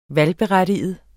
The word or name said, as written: valgberettiget